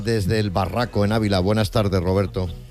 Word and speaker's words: Barraco: Desde el Barraco, Ávila.